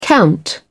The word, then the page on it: count: British English